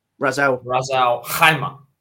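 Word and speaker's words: Ras Al Khaimah: He skipped Raz Al-Khaimah.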